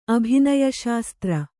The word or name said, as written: ಅಭಿನಯಶಾಸ್ತ್ರ